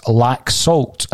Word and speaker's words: Laxalt: Now, what I would like to say, Kevin Graham is correcting me on my pronunciation of Lazolt, and he's saying that it's Laxalt, according to our Spanish correspondent.